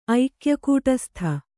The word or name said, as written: ಐಕ್ಯಕೂಟಸ್ಥ